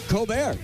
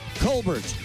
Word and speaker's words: Colbert: Stephen Colbert is the only one in his family that pronounces his name Colbert. The rest of his family pronounces it Colbert.